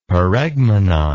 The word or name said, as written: paregmenon